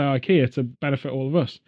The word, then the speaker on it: IKEA: So, IKEA, which we've now learned, the Swedish say IKEA. – Yeah. The correct pronunciation is IKEA.